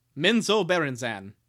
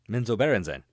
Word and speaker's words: Menzoberranzan: Menzoberranzan. – Menzoberranzan.